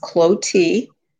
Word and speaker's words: Clottee: My name is pronounced Clotie, and I'm a second generation Arizonan.